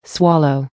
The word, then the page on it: swallow: American English